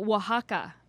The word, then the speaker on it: Oaxaca: From Oaxaca.